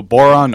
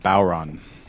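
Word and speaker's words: bodhrán: You're playing, and it's spelled B-O-D-H-R-A-N. – Right, yeah. – But it's pronounced... – So Boron, or with an Irish accent, Bowron.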